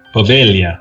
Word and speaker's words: Poveglia: It's spelled POVEGLIA. And how is it pronounced? – Povelia, I think. – There you go. That sounds right.